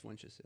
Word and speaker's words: Funches: It's Funches, yeah. – There's no L in it. – But it's spelled Funches.